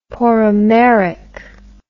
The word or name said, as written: poromeric